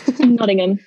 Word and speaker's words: Nottingham: Nottingham? Nottingham.